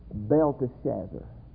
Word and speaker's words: Belteshazzar: I've been pronouncing Daniel's name as Belshazzar, but I've been pronouncing it wrong. I never did see the E in there until I saw Belshazzar's feast. And Daniel's name should better be pronounced Belteshazzar. There's a T-E in there, long E.